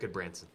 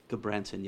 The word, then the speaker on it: Gudbranson: Eric Gudbranson. – Gudbranson, yes.